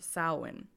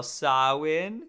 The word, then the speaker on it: Samhain: Yeah, I mentioned how to spell it because if you were reading it, you'd be like Samhain, but it's Samhain.